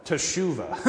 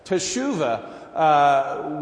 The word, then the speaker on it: Teshuvah: The Hebrew word for repentance is shetuvah. And no, I said that wrong. Teshuvah. I did that backwards. Teshuvah, which literally means to return.